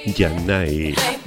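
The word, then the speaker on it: Zhané: Sané, tal como lo pronunciaremos los españoles, aunque allá dirían Yanai,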